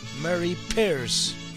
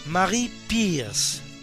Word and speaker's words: Marie Pierce: Y felicitamos hoy al vulcanólogo español Francisco Anguita, que cumple 77, y a la tenista francesa Mary Pierce. Marie Pierce. Según lo pronuncien ustedes a la inglesa o a la francesa, pues en realidad, aunque francesa de nacionalidad, nace en Canadá, hija de padre norteamericano y de madre francesa.